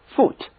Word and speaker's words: foot: 这两个 O 连在一起呢，读作短 U， foot。